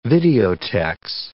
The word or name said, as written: videotex